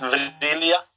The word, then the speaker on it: Vrilia: This cover artwork is by, I'm probably going to say this wrong, Relia? – I think it's Vrilia. – Oh, thanks. I knew I was going to butcher that totally. But what a wonderful... – The W is not American.